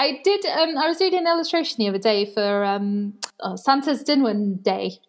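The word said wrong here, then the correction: Dydd Santes Dwynwen: I've said that wrong. Deep Santa's Dinwen. Kind of glad Emily's not here to correct my Welsh right now.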